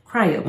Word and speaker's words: cryopreserving: Now, hours after his death, three doctors feverishly worked and completed the task of cryopreserving him. – Yes. – Did I say it correctly? – I believe so. – Cryo. – Like cryogenics.